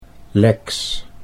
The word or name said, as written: Lex